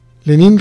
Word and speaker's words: Lenin: Es Lenin, ¿verdad? O Lenin. Lenin Ramírez. Lenin Ramírez, gracias, sin acento en la I.